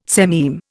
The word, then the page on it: sememe: Indian